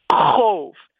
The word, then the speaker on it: Qov: Oh, by the way, your Klingon name is spelled Q-O-V. How do you pronounce that? – It's going to sound like a wind noise again. Cove. I say it rhymes with stove, except the first sound is like you're choking on spinach.